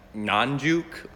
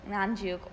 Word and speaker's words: Nganjuk: How do you pronounce an NG sound at the beginning of a word? – Oh, you mean like in Nganjuk or Ngoro? – Yes, in Nganjuk or Ngoro. – I don't know how to say it. – Yes, it is incredibly difficult. – In English, we have the NG sound, but only at the end of like listening, reading, singing, only at ING at the end.